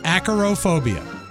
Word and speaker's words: Acarophobia: A-C-A-R-O. Acarophobia. – Acarophobia.